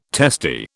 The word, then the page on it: testy: British English